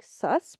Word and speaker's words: Seuss: So yeah, if you listen to it, you can hear Zeus. – In his defense, that's not how you say Dr. Seuss. It's actually like sus, but so many people said it wrong that he was like, okay, let's go with it.